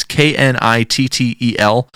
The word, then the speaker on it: Knittel: It's written by Tim, I think you would probably say his name, Knittel. It's Knittel. For no reason whatsoever, the echo just went away.